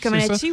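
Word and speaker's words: Comăneci: La Nadia Comaneci. – Comaneci, oui.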